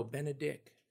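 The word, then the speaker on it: Benedick: But I use a mnemonic device in my head. It's Ben a nice guy? No, Ben a dick.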